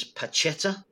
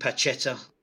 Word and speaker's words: Pacheta: A fella called Juan Jose Rojo Martin, better known as Pacheta, I think is the right pronunciation.